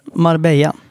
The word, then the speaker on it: Marbella: Marbella, jag vet inte hur man uttalar det. – Marbella.